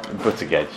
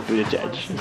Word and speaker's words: Buttigieg: How do you say it? – Buttigieg. – But I say Buttigieg.